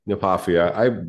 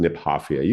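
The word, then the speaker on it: Kniphofia: By the way, I pronounced it Nepophia my whole life until the emeritus superintendent of the Arboretum told me it was named after a guy named Niphoff. So it should be pronounced Niphoffia.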